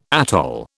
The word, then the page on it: atoll: British English